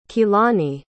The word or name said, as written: keilani